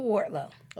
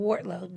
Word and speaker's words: Wardlow: My name's Sybil Wardlow. – Okay, let's spell that. Not everybody spells it the same. – S-Y-B as in boy, I-L. – That's the Sybil, okay. – And then Wardlow, W-A-R-D as in dog, L-O-W.